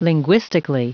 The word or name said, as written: linguistically